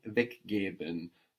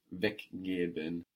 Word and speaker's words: weggeben: Weggeben.